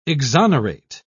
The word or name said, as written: exonerate